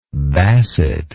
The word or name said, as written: basset